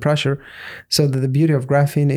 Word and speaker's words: graphene: So you did a post on something called graphene. I don't know if I'm saying that correctly. – Yeah, sure.